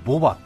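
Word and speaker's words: 牡馬: オス馬って書いてボバって読みますけどね、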